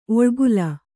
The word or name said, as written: ಒಳ್ಗಾಲ